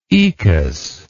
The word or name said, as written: oecus